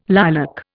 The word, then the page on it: lilac: Indian